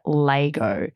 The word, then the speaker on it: Lego: Anyway, they pronounce it Lego, like L-A-Y.